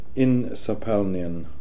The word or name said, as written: Insapalnian